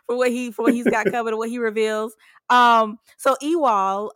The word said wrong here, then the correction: Evel: am I saying it right? Is it Ewol? Am I saying it right? I was going to say Ewol again, but I don't think that's right. – Evel. – See, look at me all over the place here. Evel.